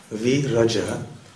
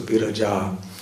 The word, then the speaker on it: Viraja: Viraja means that which is beyond the three modes of nature, and the long A at the end indicates the feminine gender, so Viraja.